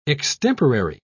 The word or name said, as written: extemporary